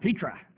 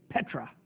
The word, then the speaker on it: Petra: He came back and he said, "it's not Petra. It's Petra. Say it right."